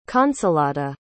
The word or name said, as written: consolata